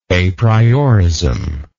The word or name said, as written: apriorism